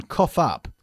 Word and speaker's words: cough: Por supuesto, se pronuncia cough y no cough. Okay, como si estuviese escrito C-O-F-F, okay?